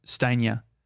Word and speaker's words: Stanier: It's Stainer. – Stainer. – It's spelled S-T-A-N-I-E-R.